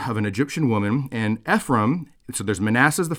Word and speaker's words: Ephraim: But Ephraim is important, or Ephraim. People pronounce it as Ephraim.